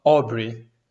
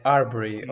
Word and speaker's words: Arbery: Eu achava que ele era Aubrey, mas não é Arbery ou whatever. Arbery.